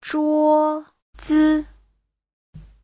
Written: zhuo1zi5

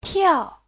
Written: piao4

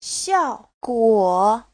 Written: xiao4guo3

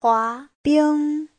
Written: hua2bing1

